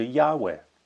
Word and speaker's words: Yahweh: So how do you pronounce that? Well, in English you can't, and so we put an A and an E in because we need vowels. We can't just pronounce a load of consonants. So we turned it into Yahweh, a name which we could pronounce.